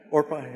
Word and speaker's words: Orpah: Am I saying that right? I called her Oprah before. Orpah, yeah.